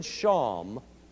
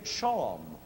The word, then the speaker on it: shawm: Then the English, who love to shorten words anyway, changed chalme to shalm, S-H-A-L-M, which could also be pronounced sham, like calm or palm. And then finally, in good old British style, it became shawm, and was even spelled that way, S-H-A-W-M.